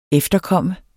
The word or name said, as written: efterkommer